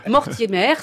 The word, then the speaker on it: Mortimer: Que je sache, Mortimer, ça rime avec lover.